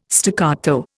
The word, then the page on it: staccato: Indian